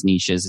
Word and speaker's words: niches: And I know it's pronounced niches. It just doesn't rhyme as well, right?